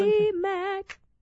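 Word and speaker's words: TobyMac: Toby Mac. That's how you're supposed to say his name.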